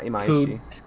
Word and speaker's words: Imaishi: It's from Imaishi. I don't know if I'm saying that right. – Yeah, Imaishi.